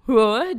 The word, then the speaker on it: Roar: Em décimo lugar, Roar, de Katy Perry. Falei certo? – Falou certinho.